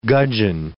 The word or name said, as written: gudgeon